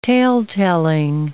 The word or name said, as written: tale-telling